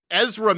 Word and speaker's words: Ezra: So Urza Miller. – You said that wrong. – Ezra Miller, there you go,